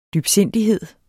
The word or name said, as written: DYBSINDIGHEDERNES